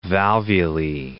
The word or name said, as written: valvulae